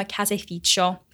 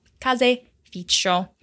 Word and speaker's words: caseificio: Allora, uh, you have a third option actually for where you can get cheese, and that is from a caseificio, caseificio, which is spelled C A S E I F I C I O, caseificio, and that's a shop that is specializing in dairy products.